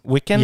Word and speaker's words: Wiccan: I can't pronounce this. W-I-C-C-A-N. – Wiccan? – Wiccan? – Yeah.